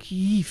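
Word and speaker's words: Kyjiw: Man spricht es ja nicht Kiew aus, habe ich jetzt auch gelernt. Sondern Kiew oder so. Weil Kiew ist die russische Aussprache, macht man nicht.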